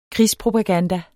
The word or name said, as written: krigspropaganda